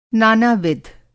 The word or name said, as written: नाना-विध